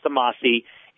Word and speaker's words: Amasai: It's spelled Amasai, but it's pronounced Amasi, and we use a proprietary fermentation process using probiotics, so our taste is more consistent.